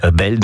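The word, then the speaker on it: BNL: Brian et Ludwig, ça fait B-N-E-L, donc B-N-L. À prononcer belle, en tout cas, c'est ce qu'ils avaient souhaité. Et ça, c'était un des premiers titres qu'ils avaient enregistré et sorti donc sur ce premier album de ce projet belle, donc qui est lui paru l'année dernière et juste avant.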